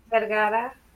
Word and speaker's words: Vergara: Vergara. – Vergara. See, I like how you say it. – Like Sophia.